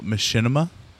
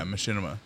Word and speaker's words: machinima: It's like the very first what they call machinima, which is a I would have pronounced that wrong. I've seen the word on paper. – Yeah, machinima.